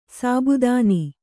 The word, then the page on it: ಸಾಬುದಾನಿ: sabudāni